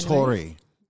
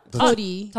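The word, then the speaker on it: Torii: The Tory. – What? – Tori.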